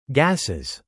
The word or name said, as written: gases